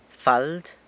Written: fald

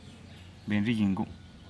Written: benriginggu

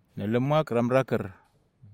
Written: nalemak ramraker

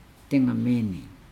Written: dengameenei

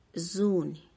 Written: zuuny

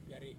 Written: piare

